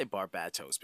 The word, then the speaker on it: Barbatos: I say Barbatos because the emphasis is on the bat. Because bat.